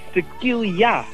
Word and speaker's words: tequila: Maar die zei tequila of tequila, hoe moet je dat nou eigenlijk uitspreken? Want jij weet het daar, hè? – Ja, het is tequila.